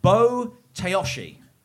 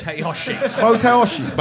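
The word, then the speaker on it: Bo-taoshi: The first sport is Bo Teoshi.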